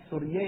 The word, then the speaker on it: سریه: در حرم خلیفه 700 زن و سوریه تلفظ درستشه من بررسی کردم از سریه میگن سوریه یعنی کنیز.